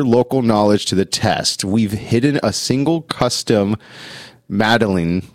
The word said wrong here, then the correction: medallion: right? How do I pronounce that? – Medallion? – Medallion. Thank you.